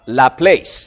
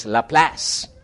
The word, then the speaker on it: LaPlace: It's not La Place, it's La Place. And it's spelled L-A-P-L-A-C-E.